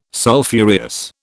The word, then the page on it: Sulphureous: British English